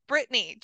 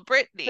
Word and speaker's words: Brittany: And I was like, no, it's just Brittany, just simple Brittany. – Phonetically correct. That's what I tell people. It's phonetically correct.